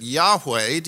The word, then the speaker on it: Yahweh: And as you probably know, this name, the I Am, turns into the word that we, as we pronounce it, we don't know how it was pronounced, we pronounce it Yahweh.